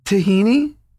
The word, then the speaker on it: tahini: And I was like, tahina. And she goes, tahini? And I go, yeah, tahina. And she goes, don't ever say that to me again.